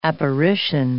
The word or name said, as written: apparition